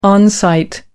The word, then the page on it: on-site: American English